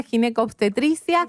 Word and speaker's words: ginecoobstetricia: Ella es médica y es especializada en ginecoobstetricia. Entonces, ¿se dice así, Gisela, ginecoobstetricia? – Exactamente.